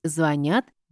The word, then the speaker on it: звонят: «Звонят» или «звонят». «Созвонимся» или «созвонимся». Чтобы запомнить, ориентируемся на начальную форму «звонить». Никаких других вариантов его произношения нет. «Я звоню», «я позвоню», «я перезвоню». Во всех формах глагола ударение статично, поэтому правильно говорить «звонят» и «созвонимся».